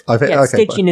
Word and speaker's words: Stygian: So we're going with Stygian, are we? – Stygian is how it's pronounced.